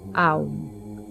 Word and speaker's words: Om: Se pronuncia Aum, y la vibración que produce en nuestro cuerpo al pronunciarlo ralentiza nuestro sistema nervioso, calmando la mente y disminuyendo la presión arterial.